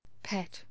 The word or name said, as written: pat